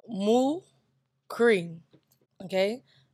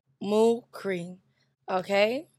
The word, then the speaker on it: Mukri: Either way, my name is Mukri. We got to go through this with y'all again. That's Mukri, okay? M-O-O-K-R-E-E. That's to help you pronounce it. Mukri, okay?